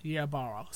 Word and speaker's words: Ybarra: First story Mike Ybarra. Ybarra. Ybarra. Said that right.